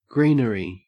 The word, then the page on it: greenery: Australian English